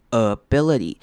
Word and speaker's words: ability: The ability，看到 ability 这个字开头是念轻音 ability，那中间两个 i 都是念成短母音 i 的声音，不要念错了 ability ability。那后面的 T 会念成 D 的声音 ability ability。